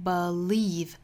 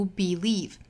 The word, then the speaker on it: believe: 我们注意后面这个 believe 这个词，它可以读成 believe，也可以读 believe，也就是前面这个音节是可以读 be，也可以读 b。弱读音节读成这个 schwa sound，读成 a，这种情况很多见。